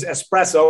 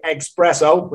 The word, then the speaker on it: espresso: At the top of the market is Espresso, or as the Americans like to say, Expresso, which there's no X in there. Learn the word properly, say it properly.